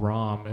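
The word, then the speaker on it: Raum: It's called ROM. It's R-A-U-M. That's how they spell it.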